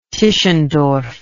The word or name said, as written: Tischendorf